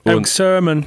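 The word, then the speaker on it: Erick Sermon: Eric Sermon. – Sermon, ja, die Betonung.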